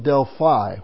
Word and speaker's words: Delphi: According to Greek mythology, under the oracles of Delphi, some pronounce it Delphi with an I, but the oracles of Delphi, that Apollos and Delphi once went to war.